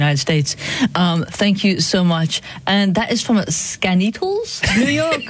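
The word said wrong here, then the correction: Skaneateles: It's actually pronounced Skinny Atlas.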